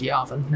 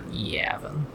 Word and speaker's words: Yavin: It's pronounced Yavin, not Yavin.